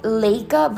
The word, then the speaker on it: Leica: Like I said, it is spelled L-E-I-C-A, spelled Leica, but pronounced Leica, and that was the Lumix.